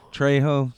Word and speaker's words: Trejo: Trejo.